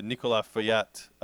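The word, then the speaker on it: Nicolas Feuillatte: The Nicolas Feuillette champagne.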